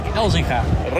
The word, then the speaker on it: Elzinga: Rick Elzinga.